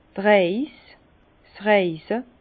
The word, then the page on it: d' Reis, 's Reise: Alsatian; Bas-Rhin; Herrlisheim